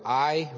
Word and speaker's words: Ai: And they take Ai, or I, really, as it's pronounced in Hebrew, and all those places.